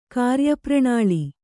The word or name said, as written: ಕಾರ್ಯಪ್ರಣಾಳಿ